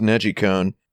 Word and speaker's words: Nejicon: I think the Nedgecon's pretty fun. Mm. The it's pronounced Nejicon, as in Nejiru means to rotate, yes, or twist, and cone for, yeah, controller.